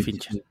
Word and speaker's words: Fincher: Pues yo me quedaría con Francis Ford Coppola, eh, me quedaría con Denis Villeneuve me quedaría con Fitchner. – Así a tres a Fincher. nunca lo pronuncio bien. Vale. David, David yo lo llamo David. – David, Fincher.